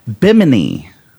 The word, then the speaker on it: Bimini: thought it was like bikini. Yeah, it's not. It's not. It's Bimini.